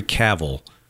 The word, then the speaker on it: Cavill: I mean, by the way, it's Henry Cavill. Rhymes with travel. I watched it. He does a video where he says it's Henry Cavill.